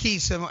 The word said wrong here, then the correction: Keenum: Case it's Keenum.